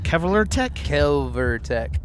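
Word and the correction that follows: Kvelertak: And if you listen to the pronunciation, which I did online just to be sure, it's Kvalotech.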